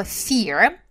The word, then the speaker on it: fear: Number one, слово fear. Fear – это страх, произносится как ear, как ухо. Все всегда путаются с произношением этого слова, но мы с вами запомним. Боимся ушей и произносим правильно fear.